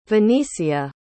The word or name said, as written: venicia